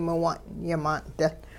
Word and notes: miwung'-yimaan'dil: Hupa

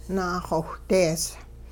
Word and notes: naaxowhdees: Hupa

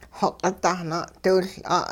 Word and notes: xoq'it-dahna'diwiL'a': hupa

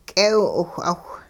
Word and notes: k'eew-'iwh'awh: hupa

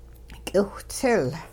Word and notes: k'iwhtsil: hupa

